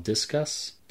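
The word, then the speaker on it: Disqus: It's through something called Discus. – Not familiar. – It's spelled like discus, like a discus that you throw.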